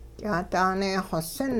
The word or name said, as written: ky'aadaanee-xosin